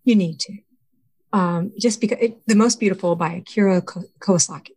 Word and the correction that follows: Kurosawa: I said it wrong. – Kurosawa. – Yeah, Kurosawa, thank you.